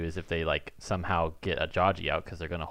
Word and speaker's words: Ajayi: First of all, it's pronounced. – It's Ajayi. – It's Ajayi.